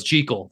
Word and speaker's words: Jekyll: And they used Robert Louis Stevenson's preferred pronunciation, which was Jekyll. That's how the author of the book wanted it to be, or that's how he said it.